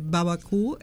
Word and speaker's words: Barbecue: Barbecue. Pero Babacú es como se pronuncia en el...